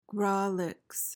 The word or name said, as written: grawlix